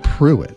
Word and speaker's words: Prewitt: And it was spelled like Prewitt, but he called it Pruitt.